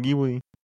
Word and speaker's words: Ghibli: Pero en japonés que la pronunciación es diferente a como se pronuncia de forma latina. No lo pronuncian Ghibli, lo pronuncian Ghibli.